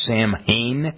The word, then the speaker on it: Samhain: Kind of, they had a festival called, I believe it's pronounced Samhain, S-A-M-H-A-I-N, recognizing the end of the harvest season.